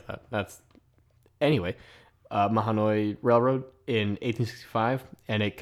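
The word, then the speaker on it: Mahanoy: They led to the Lehigh Valley Railroad building an extension called the Lehigh and Mahoney. Is that how you say it? Mahoney. Oh, thank you. This is why I have Bill here to correct me.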